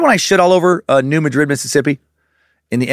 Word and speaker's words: New Madrid: Pro pizza is mediocre at best, and we pronounce it New Madrid because the Spanish pronunciation is too fancy for our tiny little no-good peckerwood brains to comprehend."